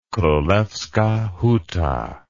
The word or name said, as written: Krolewska Huta